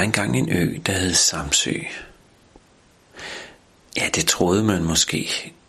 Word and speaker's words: Samsø: Men det er jo ikke helt rigtigt udtalt. I virkeligheden, så hed øen jo ikke Samsø. Den hed Samsø. Samsø.